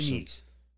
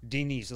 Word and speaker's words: Denis: No, my real name was D-E-N-I-S. – Dennis? How did you say it in Russian? – Denise. – Huh? – Denise, like a girl's name here.